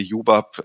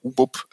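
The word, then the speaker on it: ubup: Ja, wenn, äh, ich habe mich sehr gefreut, dass Sie Jubab, Jubab ausgesprochen haben. Ja, wir haben auch andere Kundinnen und Kunden gehabt, die haben UBUB äh, gesagt oder irgendwas anderes.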